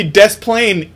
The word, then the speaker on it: Des Plaines: Why the fuck don't you do it on Des Plaines? – Yeah, it should be Des Plaines, idiot.